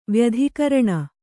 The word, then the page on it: ವ್ಯತಿಕೀರ್ಣ: vyatikīrṇa